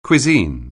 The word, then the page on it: cuisine: anglais